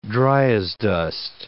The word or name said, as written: dryasdust